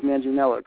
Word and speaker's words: Manginello: His last name is pronounced Manginello, correct? – Yes.